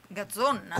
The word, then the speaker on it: Gazzon: Gazzon, attenzione a come pronunciate sto nome. – Gazzon. – Con la G? – Sì, con la... Pronuncia bene? – Gazzon. – Gazzon. Dov'è? – Come gazzosa. – Gazzon? – Gazzon con la G di Genova.